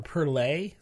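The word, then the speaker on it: Perle: Oh, pearl hops, or perlay, so I've heard that pronounced both ways. – I think that's probably the best way to say it. – Dark malts and pearlay hops, and perhaps dark chocolate.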